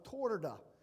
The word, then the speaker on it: Turda: That Sunday morning, I was at the Baptist Church of Torda, T-U-R-D-A. They pronounce it Torda.